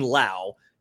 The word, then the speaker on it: Lau: The note in here is the pronunciation rhymes with now. So Brandon Lau, Nathaniel Lowe, Josh Lowe.